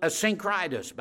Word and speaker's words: Asyncritus: Salute Asyncritus. By the way, that is the exact right pronunciation of that word, if you've ever wondered.